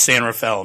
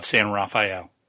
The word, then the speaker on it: San Rafael: And it's San Rafael, if we ever have to pronounce that. It's San Rafael, not San Rafael.